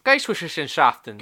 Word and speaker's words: Geisteswissenschaften: Daemon, and this German word I can't pronounce. – Geisteswissenschaften. Geisteswissenschaften. Yeah? Right, Geisteswissenschaften. – Yeah. That seems right.